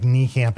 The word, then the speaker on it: Niekamp: And then, like, Niekamp, it's so long, everyone spells it wrong. I have to tell them there's a silent I.